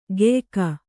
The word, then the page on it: ಗೆಯ್ಕ: geyka